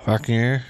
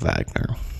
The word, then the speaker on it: Wagner: The Ride of the Valkyries is one of the most well-known pieces of Wagner. It's also spelled with a W, but the way the Germans do it, it's sounded out with a like a V.